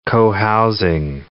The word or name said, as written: cohousing